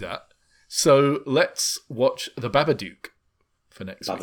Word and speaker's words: Babadook: It's like Dabaduk, Dabadike? – The Babadook.